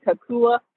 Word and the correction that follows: Kukua: Fitness. If I say it wrong, say it correctly again, Morgan. Yeah, Kukua, Kukua Fitness.